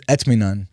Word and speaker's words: Etminan: It's Etminan.